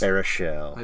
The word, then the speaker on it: Baruchel: Is that how it's pronounced? – Baruchel. – I don't like it.